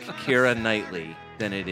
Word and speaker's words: Ciara: And some people, I'm sure plenty of people, said Ciara. – Right. – Or Sierra. – Right. – As some people would say. However, the correct pronunciation, as lots of people from Ireland pointed out, it is Kira.